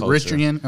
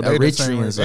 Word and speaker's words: Eritrean: the I know Greek Eritrean. Culture. Am I saying it right? – Yeah, I know Eritreans.